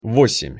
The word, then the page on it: vosem-: Russian